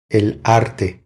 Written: El arte

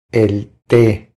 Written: El te

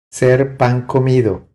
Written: Ser pan comido

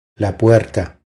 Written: La puerta

